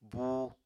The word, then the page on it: buu: Apatani